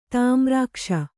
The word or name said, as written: ತಿಮಿರಾಕ್ಷ